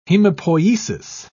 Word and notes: hemopoiesis: British English

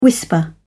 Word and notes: whisper: British English

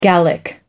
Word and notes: gallic: American English

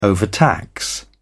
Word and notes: overtax: British English

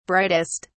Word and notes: brightest: British English

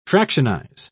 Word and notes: fractionize: British English

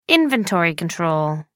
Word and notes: inventory control: American English

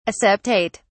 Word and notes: aseptate: British English

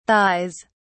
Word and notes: thighs: British English

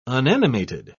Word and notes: unanimated: British English

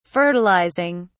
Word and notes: fertilizing: British English

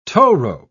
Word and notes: towrope: British English

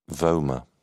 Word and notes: vomer: British English

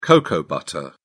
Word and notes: cocoa butter: British English

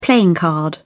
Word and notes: playing card: British English

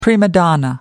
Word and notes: prima donna: American English

